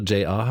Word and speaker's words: JR: Die ist gerade nicht wiederzuerkennen, denn der französische Künstler, der sich tatsächlich englisch J.R. aussprechen lässt, der französische Künstler J.R.